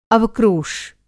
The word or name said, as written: अवक्रोश